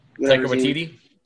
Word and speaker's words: Taika Waititi: Tiki Wa. – Taika Waititi.